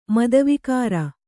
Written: ಮದವಿಕಾರ

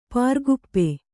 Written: ಪ್ರಕೋಪ

